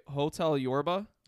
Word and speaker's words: Hotel Yorba: Hotel Yorba.